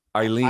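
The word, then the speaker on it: Ailin: Not a lot of fanfare on this one, but Aileen Perez. How would you pronounce A-L-L-I-N for a female? Is that Aileen? – Aileen.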